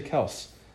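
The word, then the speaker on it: Kelce: Actually, did you know his name is pronounced Kels and not Kelsey? – I've heard this. – There's an interview with him where they asked him how to actually pronounce his last name. He's like, it's actually Kels, but everyone says Kelsey, so I got tired of fighting it, so it's Kelsey now.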